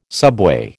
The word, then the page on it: subway: American English